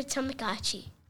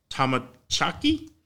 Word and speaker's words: Tamagotchi: Digit Blocks and the Tamagotchi. – Digit Blocks and Tamachaki? – No, Tamagotchi.